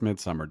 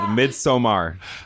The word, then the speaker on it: Midsommar: It's just Midsommar, just because there's an A. – I'm going to say it the way it's spelled, Midsommar. – It's Sommar if there's two M's.